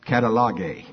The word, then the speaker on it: katallage: K-A-T-A-L-L-A-G-E. And it looks like they're pronouncing that catalogue.